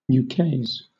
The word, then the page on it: ukase: British English